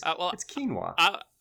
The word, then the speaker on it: quinoa: I didn't know how to pronounce quinoa. I always thought it was quinoa in my head, right? I would see it. It's quinoa. But no, it's quinoa.